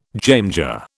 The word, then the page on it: jamjar: British English